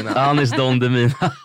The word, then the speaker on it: Anis Don Demina: Ja, Don Demina, Demina, herregud, alltså för lyssnarnas skull så är Danis Don Demina. – Anis Don Demina.